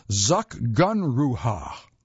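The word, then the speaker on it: Zugunruhe: It's the season of zagunrui. Well, didn't quite pronounce it right. They tell me in the next sentence how to do it. What a delicious word this is! Zakgunruha. Zakgunruha. It might not gently roll off the tongue, but its meaning is just as beguiling as the funky pronunciation.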